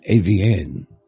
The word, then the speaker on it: Avien: It's named Avian. – It's spelled like this. A-V-I-E-N. – Okay. – Anyway, A-V-M.